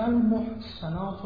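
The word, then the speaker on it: المحصنات: اولا فرمود و المحسنات من المؤمنات، شما زنان محسنه مؤمنه، اینجا محسنات با سین نیست، با صاد تلفظ می شود،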